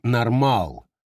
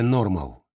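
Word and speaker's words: normaal: Все вместе вызывало состояние, которое голландцы именуют «нормал», с ударением на втором слоге. Совсем не то же, что английское «нормал».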